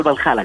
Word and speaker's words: Valhalla: Oye, ¿y este desafío vikingo OCR rumbo al Balalá? – Rumbo al, rumbo Valhalla, exactamente. Al, Valhalla. Oye, el Valhalla, ¿cómo se dice? ¿Bajala o bajala? Valhalla.